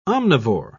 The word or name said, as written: omnivore